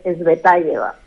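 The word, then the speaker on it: Tsvetáyeva: no, no, exactamente, no exactamente, se dice Svetayeva.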